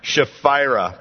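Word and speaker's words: Chephirah: Now their cities were Gibeon and Sheriff. Oh boy, I had all the confidence in the world going into that one. And I just, how do we say it? Shephira. There we go.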